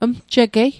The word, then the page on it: mjigei: Mi'gmaq